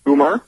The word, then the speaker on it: Umar: Umar? – Yes.